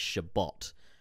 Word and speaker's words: Shabbat: Traffic going for correcting me, it is pronounced Shabbat, which makes more sense.